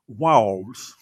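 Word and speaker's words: Vowles: Jag, jag har jättesvårt då att uttala hans namn, men han heter något Vowls. – Wows. Ja, det är bra.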